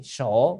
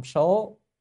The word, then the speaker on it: sổ: Sổ, cái chữ này âm số, số là cái danh, là danh số, là số mục, nhưng mà phải đọc là sổ. Sổ là tính từ, là vài mấy,